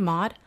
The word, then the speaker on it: Maud: Maud? – Yeah.